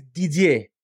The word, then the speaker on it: Didier: My name is Didier, okay? It's French.